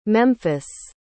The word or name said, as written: Memphis